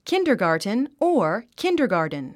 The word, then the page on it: kindergarten: kíndə r gɑ`ː r tn; キンダァガーテン